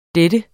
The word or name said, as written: dennes